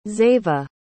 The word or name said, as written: zeva